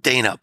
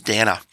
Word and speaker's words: Dana: Her name is spelled Dana, but it's actually pronounced Dana.